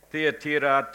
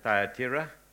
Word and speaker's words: Thyatira: Okay, Thyatira, Thyatira, doesn't matter, they're all correct. Doesn't matter how you pronounce it. I pronounce it Thyatira, but that's fine.